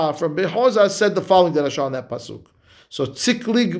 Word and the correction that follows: Tziklag: midam, let's see the right pronunciation. Tziklag.